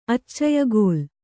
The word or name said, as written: ಅಚ್ಚಯಗೋಲ್